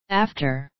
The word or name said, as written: after